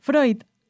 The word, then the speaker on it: Freud: Freud.